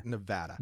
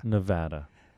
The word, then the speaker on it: Nevada: Nevada. – Nevada.